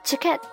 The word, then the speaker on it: チケット: 呃，这个 ticket 我们知道是可以代表一切的票据啊。那么在本句话中呢，它代表的就是机票的意思。它的日文发音 ticket 有一个促音啊，k 和 to 之间是一个促音，发作 ticket。